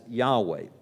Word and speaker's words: Yahweh: We don't really know with certainty how this word is pronounced. We have filled in the vowels and we pronounce it as Yahweh. It's connected to the verb hayah, which would be H-Y-H, which means to be.